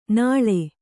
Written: ನಾಳೆ

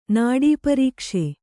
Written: ನಾಡೀಪರೀಕ್ಷೆ

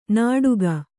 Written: ನಡುಕ

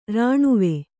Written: ರಾಣವೆ

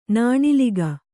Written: ನಾಣಿಲಿಗ

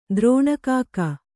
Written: ದ್ರೋಣಕಾಕ

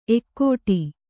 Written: ಎಕ್ಕಡಿ

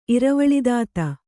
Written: ಇರವಳಿದಾತ

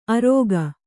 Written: ಅರೋಗ

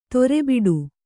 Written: ದಾರಿಬಿಡು

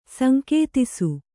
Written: ಸಂಕೇತಿಸು

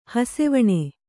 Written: ಹಸೆವಣೆ

